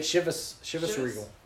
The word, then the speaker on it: Chivas Regal: Is it Chevitz? – Chevitz, maybe. – Chivas Regal. Yeah. – Okay, I said it right.